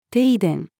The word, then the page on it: 停電: female